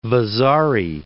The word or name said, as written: Vasari